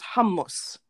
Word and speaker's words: hummus: No, he said it in a way that most people of that region would say it, which is hummus. – Damn. – That's how you say it. It's hummus. – That's what you're going to name your kid.